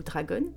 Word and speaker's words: Dragon: et qui est surnommé Dragon ou Dragon. Et si je le prononce comme ça, c'est parce que c'est un Français.